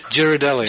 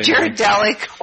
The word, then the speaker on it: Ghirardelli: And we have Ghirardelli, chocolate. The chocolate, what's it called? – Yeah, you pronounce that one really well. – I can't remember what it is. – Ghirardelli. – Ghirardelli.